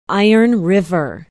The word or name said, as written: iron river